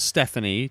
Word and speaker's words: Stephanie: Okay, so it is pronounced Stephanie, just like it's, yeah. There's not some crazy Stepana Hayi or anything. – No. – Okay. – I'm from Quebec, so it's Stephanie, but you can say Stephanie.